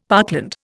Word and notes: parkland: Indian

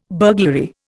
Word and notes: burglary: Indian

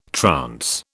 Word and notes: trance: British English

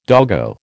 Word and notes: doggo: American English

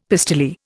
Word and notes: pistoleer: Indian

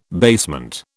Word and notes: basement: British English

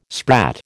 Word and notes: sprat: American English

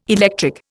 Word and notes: electric: Indian

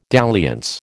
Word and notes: dalliance: American English

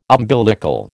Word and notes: umbilical: American English

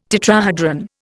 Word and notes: tetrahedron: Indian

